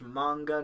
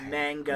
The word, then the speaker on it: manga: It's pronounced manga, not manga.